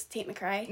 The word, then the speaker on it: Tate McRae: No, it is Tate McRae.